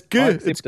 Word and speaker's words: GIF: GIF is really pronounced like GIF. Some people are still like, blah, blah, blah. It's a G. It's right. A hard G. It's GIF.